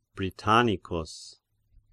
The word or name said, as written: Britannicus